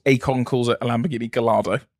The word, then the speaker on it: Gallardo: It's not difficult to say Gallardo.